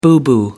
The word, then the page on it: boo-boo: American English